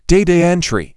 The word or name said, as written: Data entry